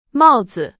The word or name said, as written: 帽子